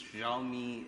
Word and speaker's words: Xiaomi: This is one of the questions that heard from just about how do you pronounce Xiaomi? Is it Xiaomi? It's Xiaomi.